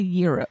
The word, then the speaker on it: gyro: How's that one pronounced? – It's spelled different, like with with a Y. – G-Y-R-O. – I think that's a Euro.